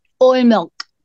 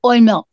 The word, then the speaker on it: Oimelc: So in Scots Gaelic, that this same holiday that we are celebrating today, hallelujah, is called Oimilk, and it is spelled colloquially O-I-M-E-L-C or K, Oimilk.